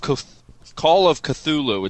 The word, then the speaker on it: Cthulhu: Oh, Call of Cthulhu.